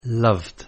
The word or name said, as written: loved